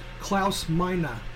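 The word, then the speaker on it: Klaus Meine: I think it's pronounced Klaus Meiner of the Scorpions.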